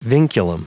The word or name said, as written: vinculum